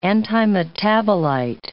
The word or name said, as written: antimetabolite